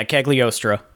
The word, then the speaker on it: Cagliostro: Yeah, Cagliostro.